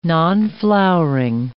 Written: nonflowering

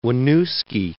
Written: Winooski

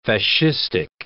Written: fascistic

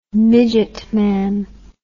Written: midgetman

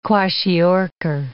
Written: kwashiorkor

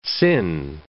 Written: sin

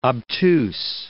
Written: obtuse

